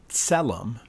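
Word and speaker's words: tselem (צֶלֶם): At its core, and this is what the Hebrew word means, it's pronounced tselem. It's like a T-S-E-L-E-M sound, if you want to kind of spell that out and sound that out.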